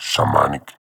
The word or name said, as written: Shamanic